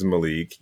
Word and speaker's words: Malik: So my name is Malik. You spell it M-A-L-I-K.